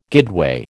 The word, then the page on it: guideway: American English